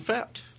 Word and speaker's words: Fett: Also joining us today in the studio, we have a very special guest, friend of the show, Jordan Fett. – Thank you. Good to be here. – Did I pronounce that correctly? – You did, yeah. – It's like boba? – Like boba.